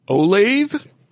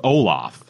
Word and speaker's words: Olathe: O-L-A-T-H-E. How would you pronounce that? – Olathe? – I thought it was Olaf. – Olaf? – But it was Olathe.